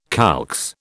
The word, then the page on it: calx: British English